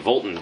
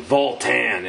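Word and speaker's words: Vultan: And it's not the right way to say Volton. Doesn't sound right at all. It's like Voltan. It has to have that, like, you know, good second syllable to it, because it's, for God's sakes, it's Voltan. – Yeah, otherwise, it sounds like you're saying Vulcan.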